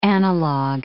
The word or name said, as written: analog